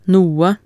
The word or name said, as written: noe